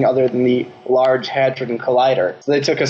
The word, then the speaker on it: Hadron: How do you pronounce the large... – Hadron. – Hadron. The large Hadron Collider. – The R and the D in hard-on. – Yeah, Hadron. H-A-D-R-O-N. – Yes, not H-A-R-D-O-N.